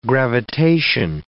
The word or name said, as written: gravitation